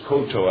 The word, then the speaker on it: kowtow: Now, he didn't say kowtow, and that's not the correct way to pronounce it. It used to be the dictionary was koto, I think, but we say kowtow, so you'd wonder what I was saying if I said the other.